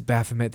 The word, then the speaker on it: Baphomet: But, like, how did you say that? Sorry. I thought it was Baphomet. I might be saying it as really dumb American. – No, it's Baphomet. There's also a Bahamut that I really struggle with saying Baphomet the right way because it...